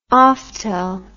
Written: oftel

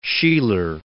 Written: Sheeler

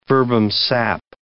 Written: verbum sap

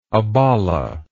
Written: abolla

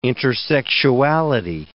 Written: intersexuality

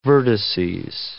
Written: vertices

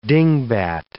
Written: dingbat

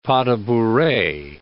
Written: pas de bourree

